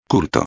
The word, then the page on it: culto: espanhol